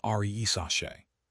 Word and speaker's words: Risacher: The Hawks, for their part, moved on from their former first overall pick, Zachary Risacher, pronounced Z-A-H-Kari R-E-E-Sache.